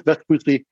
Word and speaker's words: Vespucci: Did I pronounce Vespucci, Lars, correctly? – Yes, absolutely.